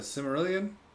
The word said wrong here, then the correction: Silmarillion: Can I see that? – Cimmerillion? – Where is that? Where are you seeing that word? Silmarillion.